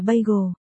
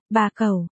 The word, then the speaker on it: bagel: Phải đọc là bagel, bà cầu, bagel, chữ cầu phải uốn lưỡi lên, bà cẩu, stupid, Lục Kiều Kiều đột nhiên phát âm rất chuẩn.